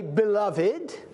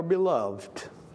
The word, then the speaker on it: beloved: But beloved is a word that can be pronounced either as a three-syllable word or a two-syllable word. You can say beloved or beloved.